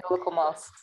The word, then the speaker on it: Locomouse: como a Locomouse, acho que é assim que se Exato. pronuncia. É, é Locomouse.